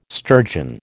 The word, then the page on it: sturgeon: American English